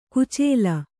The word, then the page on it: ಕೊಚ್ಚಲು: koccalu